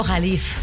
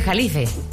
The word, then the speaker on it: Jalife: Entonces yo le dije, entonces explíqueme quién es Alfredo Califa. – Alfredo Jalif. – Ah, Jalif, eso. – Se escribe Jalife. – Jalife. – Jalife. – Sí, es Alfredo Jalif Ramírez.